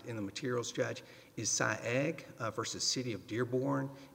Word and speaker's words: Saieg: And spell Sy-Egg for me. – It is Sy-Egg. Yes, Your Honor. Sy-Egg, S-A-I-E-G, if I'm pronouncing it correctly.